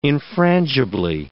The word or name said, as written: infrangibly